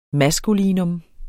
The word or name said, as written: maskulinum